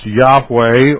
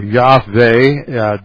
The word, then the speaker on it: Yahweh: and it is sometimes pronounced Yahweh or Yahweh.